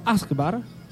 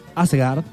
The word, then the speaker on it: Asgard: el árbol se llamaba Yggdrasil, Yggdrasil, en cuya copa se hallaba Asgard, lo dije mal, Asgard.